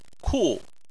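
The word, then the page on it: ku: tone 4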